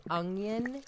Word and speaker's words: onion: My mother used to say onion with U-N-G. Y-U-N. Onion. – Like Funyun. – Yeah. It's onion. Onion. – Yeah. – Onion. – It's three syllables, I believe, onion, right? – Onion.